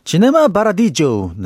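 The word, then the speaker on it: Cinema Paradiso: 이탈리아어로 한번 발음해 볼까요? 시네마 바라디조 네 이렇게 두 곡 네 지금 바로 우리 EBS 라디오 1 스튜디오에 있는 그랜드 피아노로 직접 라이브 연주 해드릴게요.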